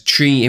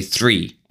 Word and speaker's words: three: I always, and you probably have heard this before, I always share the example where if a student um, says tree in my lesson, I start drawing trees, and the student realizes, oh, I'm, I'm pronouncing it wrong. I should say three and that's, you know, that's that's providing feedback on language.